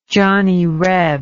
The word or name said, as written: johnny reb